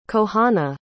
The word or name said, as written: kohana